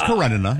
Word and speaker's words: Karenina: It's Karenina. You're right. – Thank you. – Karenina.